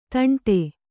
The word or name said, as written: ದಂಡೆ